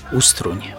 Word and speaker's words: Ustroń: Gwarowo miejscowi nazwę miasta wymawiają Ustroń.